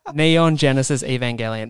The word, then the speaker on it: Neon Genesis Evangelion: Neo Genesis Evangelion. – Neon Genesis Evangelion. – Fuck. Neon Genesis Evangelion.